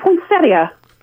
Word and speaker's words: poinsettia: And it is poinsettia. – Poinsettia. And why why the extra syllable in there? – Well, it's not an extra syllable. It's an extra letter. There's an I-A, which is an ia, right? – Mm-hmm. – So it's poinsettia.